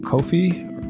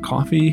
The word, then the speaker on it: Ko-fi: Um, and you can do that on a website called Kofi or Coffee, but it, it's spelled K O F I.